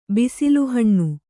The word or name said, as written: ಬಿಸಿಲುಹಣ್ಣು